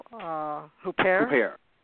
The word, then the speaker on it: Huppert: I might not be pronouncing this last name right. The French actress, Isabelle Huppert. Is that? – Mm-hmm.